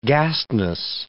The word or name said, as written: gastness